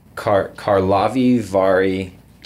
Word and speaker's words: Karlovy Vary: By golly, you're pretty darn close. – Okay. – But I must quickly say I cannot criticize you because I just returned from Karlovy Vary, as I pronounce it, this last week after their annual festive opening of the spa season. And I heard them, the locals, pronounce it two different ways.